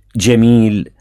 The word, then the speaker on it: جميل: The Iraqi stallholder uses the soft ž pronunciation, but the Egyptian stallholder uses the hard g. You can hear this in the phrase a beautiful gold ring. – خاتم جميل ذهب.